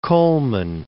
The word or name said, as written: Colman